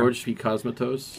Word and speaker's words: George P. Cosmatos: George P. Cosmatos.